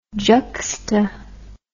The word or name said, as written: juxta